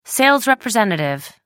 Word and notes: sales representative: British English

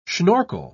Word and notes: schnorkel: British English

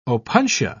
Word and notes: opuntia: British English

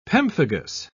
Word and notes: pemphigus: British English

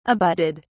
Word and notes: abutted: British English

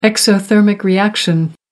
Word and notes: exothermic reaction: American English

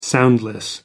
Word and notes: soundless: American English

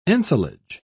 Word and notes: ensilage: British English

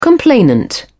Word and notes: complainant: British English

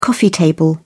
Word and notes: coffee table: British English

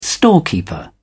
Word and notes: storekeeper: British English

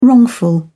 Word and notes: wrongful: British English

